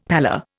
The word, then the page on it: pallor: Indian